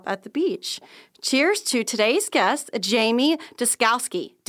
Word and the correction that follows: Descalis: Did I say it right? – Descalis. – Okay. Jamie Descalis.